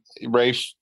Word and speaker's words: Rafe: Yeah, I mean, Rafe, R-A-F-E.